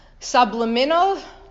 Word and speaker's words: subliminal: A person may find, in order for ahava, love, and yira, reverence of God, to be not merely, here we go, subliminal, like from sublime, what? – Subliminal.